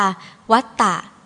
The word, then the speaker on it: วัตตะ: วัตตะคำว่าวัตตะ